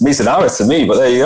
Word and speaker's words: Mizunara: Yeah, so this is the Mizunara.